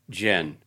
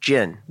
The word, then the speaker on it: djinn: How do you pronounce that? A djinn? – Jin. – Just gin. – Jin.